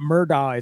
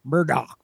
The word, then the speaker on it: Murdaugh: And he properly schooled me on the way to say, it's not Murdaugh, it's Murdaugh. – Murdaugh. – Sounds like Shelby. – He lived in that area for a while and said, yeah, it's pronounced different. Up here, it's Murdaugh, but it's Murdaugh down there.